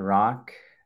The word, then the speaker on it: Rak: My name is Dr. Rock. That is spelled R-A-K, pronounced R-O-C-K.